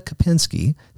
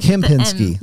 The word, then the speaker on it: Kimpinski: But anyway, we have with us Jenna Kimpinski. Did I say that right? – Pretty close. – Pretty close. Okay, how did I mess it up? – It's Kimpinski. – Kimpinski.